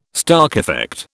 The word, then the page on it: Stark effect: British English